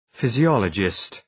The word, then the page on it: physiologist: ,fızı’ɒlədʒıst